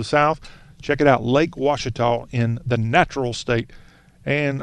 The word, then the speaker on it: Ouachita: Lake Ouachita is in Arkansas. Don't be confused. It's O-U-A-C-H-I-T-A, but pronounced Ouachita.